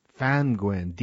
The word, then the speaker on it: Ddantgwyn: And as you say, his name was Owen, I think they pronounce it Fangwin. – Fangwin, D-D-A-N-T. Yeah. – G-W-Y-N. It's D-D. That's it. D-D-A-N-T-G-W-Y-N.